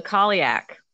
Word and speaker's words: Cailleach: I'm going to spell it. C-A-I-L-L-E-A-C-H. So that is an aspect of Breed as the crone. – Oh, the Kaliak.